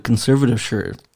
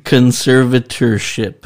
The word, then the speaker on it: conservatorship: What the fuck a conservative shirt conservative shirt is shit? Kudos on the pronunciation. Conservatorship. Conserv- Say it with me. – Conservatorship conservatorship.